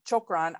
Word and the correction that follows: shukran: I say it incorrectly, probably. – Yeah, shukran.